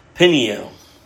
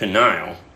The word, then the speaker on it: Peniel: When I was going reading through this and struggling with it, I used to pronounce that Peniel, but I have a Bible software now that I can click words and it tells me how to pronounce things properly. So apparently it's Peniel, but it's kind of hard for me.